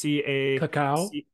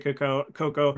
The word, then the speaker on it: cacao: So what, to preface that, there, you know, chocolate in its original form, which is cocoa, not with the two O's, but with two A's. So it's cacao. – Cacao. Some people pronounce it cocoa.